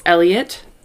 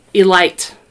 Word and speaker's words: Elytte: That's a big reason why I had to look for a video for her accomplice, because his name is Elliot. But it's spelled E-L-Y-T-T-E. – Oh. – It looks like Elite or Elity.